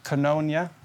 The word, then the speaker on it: koinonia: And, you know, Andrew, I love how you spoke about koinonia.